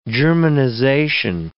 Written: germanization

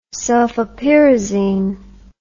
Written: sulfapyrazine

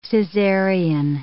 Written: Caesarian

